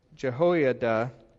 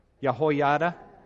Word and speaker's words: Jehoiada: because it's the high priest Jehoiada who is, and I'm not sure if I'm saying that right, Jehoiada? Okay. Thank you for helping me out, my Hebrew scholars. Jehoiada? Let's call him that.